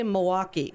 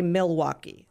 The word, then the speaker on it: Milwaukee: We say Milwaukee. We don't say Milwaukee. – Milwaukee.